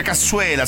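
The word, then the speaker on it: cassoeula: Poi altre ricette con la verza e la nostra cassuera. – No, non si dice cassuera, lo dici solo tu, si dice cassuela, sei l'unico che lo dice con la R.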